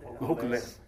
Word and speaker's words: Hokulea: With the Hanalea? Hokulea. Yeah. Pronounce it like a native.